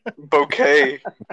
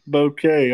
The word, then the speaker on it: bouquet: Now, how did you say that word? – Bouquet. – Bouquet, okay. It don't look like it.